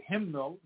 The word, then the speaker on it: hymnal: Because when I spell it H-Y-M-N, I just say him. But then I say, if you say hymnal, now I'm saying the N.